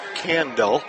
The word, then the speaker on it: Kandel: Leading off the shortstop is Andrew Kandel, spelled K-A-N-D-E-L, pronounced Kandel.